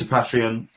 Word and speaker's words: Patreon: You started up your Patreon. Am I pronouncing it right? – Yes, I have.